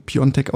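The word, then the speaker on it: Piątek: Schistoff Piontek, Piatek geschrieben, Piontek ausgesprochen, macht es uns leicht, kommt uns entgegen und sagt, nennt mich doch einfach Chris.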